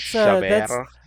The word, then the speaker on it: Chabert: And you have to French that up, sir. That's Chabert.